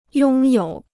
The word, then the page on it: 拥有: yōng yǒu